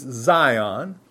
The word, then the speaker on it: Zion: He says in verse 22 of Hebrews 12, but you are come unto Mount Sion. Now it's spelled with an S here in the New Testament, but it's Zion with a Z, same thing.